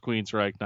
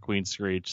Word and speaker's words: Queensryche: Yeah, it's Queensryche, not Queensreach.